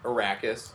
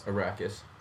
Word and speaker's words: Arrakis: Arrakis. – Arrakis.